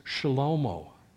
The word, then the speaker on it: Shlomo: His son, we call him Solomon, but that's not how his name is pronounced in the original language. It is Shlomo.